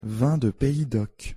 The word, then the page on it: vin de pays doc: French